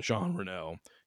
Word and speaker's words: Jean Reno: It's Jean, like J-E-A-N, and then Reno, Jean Reno.